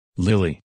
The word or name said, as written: Lily